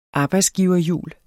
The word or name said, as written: arbejdsgiverjul